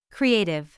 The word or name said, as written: creative